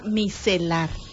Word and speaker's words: micelar: Para empezar, decime bien la pronunciación. – Agua micelar. – Bueno, dentro de lo que yo tengo idea en el diccionario chapaneco, el agua miserable. – Micelar. – El agua miserable. El agua micelar.